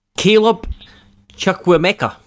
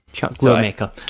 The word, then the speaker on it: Chukwuemeka: Hang on, how do you say his name? – Caleb Chukwuemeka. – Yeah, that's actually right. – For fuck's sake, you've ruined the end of the podcast. – There we go. – It's just basically how it's spelt, but you don't really pronounce the E.